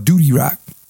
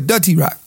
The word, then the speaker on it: Dutty Rock: I'm sorry if I'm saying it wrong. I used to call it Duty Rock. But anyway, Dutty Rock.